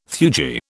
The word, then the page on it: theurgy: British English